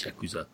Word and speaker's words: Yakuza: The pronunciation is tricky. But I think the U shouldn't be stressed too hard, so it's Yakuza, I think.